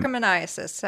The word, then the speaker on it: trichomoniasis: Trichomoniasis.